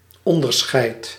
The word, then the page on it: skillnad: /ˈɧɪlˌnad/